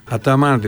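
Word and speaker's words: Ataman: Άταμαν λέει, σωστά το λέτε, είχα ακούσει τον Τερίμ να τον λέει Αταμάν, δεν ήξερα τι έλεγε. Ερντογάν λέμε, Ερντογάν δεν λέμε, ναι. Σουλεϊμάν, Μπαϊκάλ, Τσιλέρ, Τάντσου, Τσιλέρ. Θυμάστε Τάντσου. Όλες οι τουρκικές λέξεις τονίζονται στη λίγουσα.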